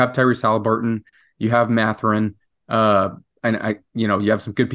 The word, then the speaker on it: Mathurin: Yeah, Aaron, your Benedict Mathurin pronunciation has to be much more French Canadian than that. It just Mathurin. – Has to be Mathurin. Mathurin. Mathurin is what we kind of were rolling with on the pod. Yeah. I like the T with the silent H.